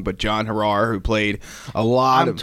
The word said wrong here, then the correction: Harar: Says Hera. – Hera, okay. – Yeah. He said he was told several different pronunciations sure. – along the way, but it's Hera.